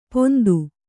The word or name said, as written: ಪೊಂದು